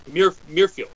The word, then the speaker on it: Muirfield: Muirfield. I don't know how to say it right. Muirfield. – Muirfield. – There you go. That's it. – Like the John Muir Trail? – I guess.